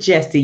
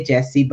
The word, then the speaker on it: Yesse: That's Yes with Yesse, because it starts with a Y. I pronounce it Yesse.